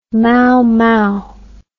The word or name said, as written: maomao